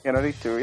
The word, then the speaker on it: Iñárritu: directed by Alejandro González Iñárritu? Iñárritu? Have we agreed upon that? – Iñárritu, yes. I think that's the proper pronunciation.